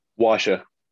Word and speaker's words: Wascha: Wascha. – Wascha, not wax yep.